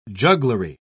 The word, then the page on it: jugglery: British English